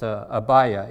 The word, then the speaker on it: Abia: In the New Testament, it's pronounced Abia in this case.